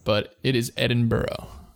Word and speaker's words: Edinburgh: But it's spelled Edinburgh. – That's pronounced Edinburgh? – It's Edinburgh. Yeah.